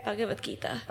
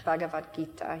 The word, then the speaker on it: Bhagavad Gita: Bhagavad Gita. – Bhagavad Gita, yes.